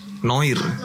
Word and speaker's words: Noire: Bacanya apa? Noir. Noah, Noir. Noah, Noire. – Noire. Noir. Nah, Noir. – Noir. Yang benar yang apa? – Noir. – Noir ya. Benar.